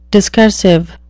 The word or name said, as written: discursive